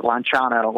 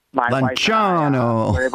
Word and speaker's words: Lanciano: So the first one was in the 8th century in Lanciano, Italy, or Italians pronounce it Lanciano when Lanciano. – Exactly, Lanciano. You got to have your fingers in the air when you say Lanciano.